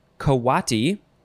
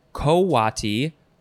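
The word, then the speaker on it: coati: Next is a word that looks like it would be pronounced coty, but it is not. It is uh, you've got a few pronunciations. Kawati, uh, also, well, that's very similar. So Kawati, Kowati, or Kwati.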